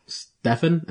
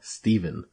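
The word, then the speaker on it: Stephen: I learned that people get really, really angry when their name is spelled that way, but you call them Stefan instead of Steven. And I don't understand. It's a PH. Why the hell is it Steven? – I don't know.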